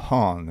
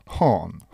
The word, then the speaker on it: Han: Han, me treenisime väga pikalt koos hiinlastega, et kuidas seda õigesti hääldada. – Han, Han.